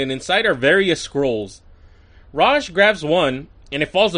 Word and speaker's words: Ra's: So Ra's shows the puffy pants we saw earlier, which, okay, Ra's, I call him Ra's. I know now it's pronounced Ra's, but in this cartoon he's called Ra's.